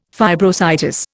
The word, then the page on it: fibrositis: Indian